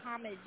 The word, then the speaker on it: homage: Now, homage? – It's a French word. Right. Homage is the English pronunciation, but it's a French word. It's homage.